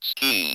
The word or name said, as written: ski